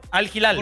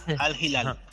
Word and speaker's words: Al Hilal: Aparte hacen la H como así como, sí, como que no queriendo, sí, pero sí es Al Hilal.